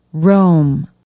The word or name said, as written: rome